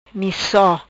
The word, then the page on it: Mico: patua